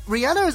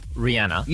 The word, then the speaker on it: Rihanna: Anyways, number two, Rihanna is asking her fans...